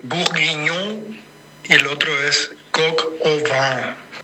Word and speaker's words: coq au vin: Y después el otro es el coq au vin. Ah, yo dije vin, ¿viste? ¿Viste lo que es el tipo que sabe? Coq au vin.